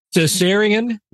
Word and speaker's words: Caesarion: Hey, man, I can't pronounce this right. Caesarion, was he really Caesar's son? That's a you topic, Vic. And then particularly haunted places. – Wow. I'd say let's take Caesarion out of that one, but fair enough. I did say it right. – Yes, you did.